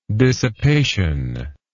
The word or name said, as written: dissipationless